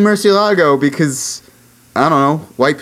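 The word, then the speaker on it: Murciélago: Well, you can tell it's Murcielago uh, because there's literally an accent on the e.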